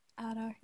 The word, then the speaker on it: Adar: I feel like I'm still pronouncing it wrong. Adar. – Adar, yeah.